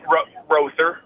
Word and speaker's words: Rother: about, how do you pronounce that last name? – Is it Rother or Rother? You pronounced it Rother. – It's Rother.